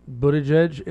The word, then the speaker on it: Buttigieg: It's like I think Buttigieg is probably the most likely candidate, and I'm pronouncing that correctly. It's not nuclear.